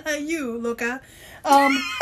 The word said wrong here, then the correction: loca: but Lo- you said it wrong. Loca. – Loca.